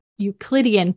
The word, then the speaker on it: Euclidean: In gravitational fields, there are no such thing as rigid bodies with Elucidian properties. That's really, really, really damn good to know. oh no, I said that wrong. Not Elucidian. Okay.